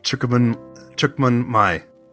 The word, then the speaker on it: Chúc Mừng: You pronounced that very well just now, by the way. So, yes, great work. So it's Chúc Mừng. – Chúc Mừng. – Chúc Mừng.